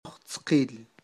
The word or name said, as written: ojztqil